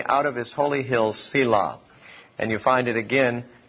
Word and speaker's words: Selah: Selah. We spell it S-E-L-A-H. Though in the Hebrew, it's only consonants. There's no vowels in it in the Hebrew. And so Selah is an assumed pronunciation, though we don't know if that's correct or not.